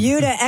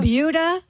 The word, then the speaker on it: Buda: It's called the Buda... – It's spelled B-U-D-A, and I looked it up. It is pronounced like beauty.